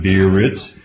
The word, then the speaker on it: Beerritz: B-E-E-R-R-I-T-Z is how you pronounce it, Bieritz.